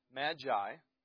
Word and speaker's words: magi: And the word there for wise men is literally pronounced magi.